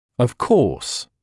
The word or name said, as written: of course